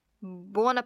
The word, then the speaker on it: Buona: Buona Pasqua. – I said it wrong. Buona. – B-U-O-N-A, so buona.